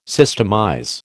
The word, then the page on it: systemize: American English